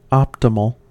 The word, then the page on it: optimal: American English